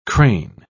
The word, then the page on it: cran: British English